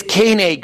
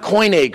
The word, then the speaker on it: Koine: All of my professors at all the universities I've gone to, every American I know mispronounces it's Koine Greek, not Koine Greek.